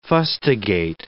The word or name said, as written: fustigate